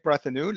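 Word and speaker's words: Brethenoux: So my name is Eric Brethenew, like you rightly pronounced, which is actually quite amazing that people do that these days.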